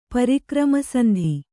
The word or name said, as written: ಪರಿಕ್ರಮಸಂಧಿ